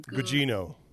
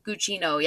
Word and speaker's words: Gugino: Gugino. – Gugino, yeah.